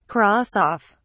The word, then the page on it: cross off: British English